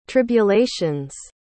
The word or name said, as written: tribulation's